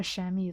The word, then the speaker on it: chamois: It's pronounced Chamois. And you're probably wondering, where the fuck is the S? I don't know either, man. I think it's French.